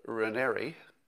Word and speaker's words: Raniere: So Allison Mack was sort of the, you know, people called her like the second in command at Nexium under Keith Raniere, rhymes with canary, I'm told.